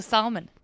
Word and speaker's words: Salmon: So if you're looking for me online, S-A-L-M-O-N, like the fish, that's how you do it. – So Salmon.